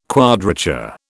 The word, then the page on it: quadrature: British English